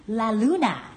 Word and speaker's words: la luna: La Luna. Luna. That's how you say it in Spanish and Italian, and I believe in Portuguese as well. – Okay. – In French is La Lune.